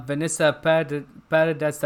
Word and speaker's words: Vanessa Paradis: Yeah, like I said, this actress of, well, if you don't know, that's actually Vanessa Paradis. That's how you pronounce her name.